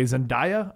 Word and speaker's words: Zendaya: And now we have the new MJ Zendaya. I think is how you say her name.